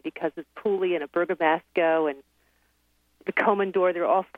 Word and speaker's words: Bergamasco: Bergamasco.